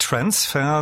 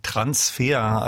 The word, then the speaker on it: Transfer: Während die Musik gerade lief, erzähltest du mir, wird nicht Transfer, sondern Transfer, also deutsch ausgesprochen.